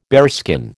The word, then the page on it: bearskin: American English